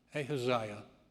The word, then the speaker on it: Ahaziah: After Ahab's death, Moab rebelled against Israel, and it's pronounced Ahaziah.